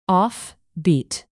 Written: offbeat

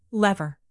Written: lever

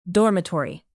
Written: dormitory

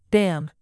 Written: bam

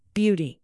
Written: beauty

